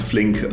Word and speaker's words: Flink: Äh, wie werden sie ausgesprochen? Rolik, genau, und Flink, also quasi was passiert im LEH in Deutschland.